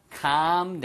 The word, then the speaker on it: calm: 발음만 길게, come이 아니에요. come down은 내려와 라는 뜻이니까 hey, come on, come down here 이렇게 쓰이니까 이거는 calm down, 애리 묵음이고요.